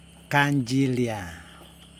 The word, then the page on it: kandJilia: Yokoim